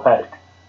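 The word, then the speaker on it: Patek: It's actually, the funny part about the pronunciation of the word. Thug was right. It's called Paddock.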